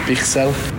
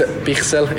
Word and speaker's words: Bichsel: Bichsel. – One more time. – It's Bichsel. It's in the end, it's a bit, or it's hard. Like in Skåne, some people can pronounce it like that.